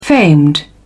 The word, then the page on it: famed: British English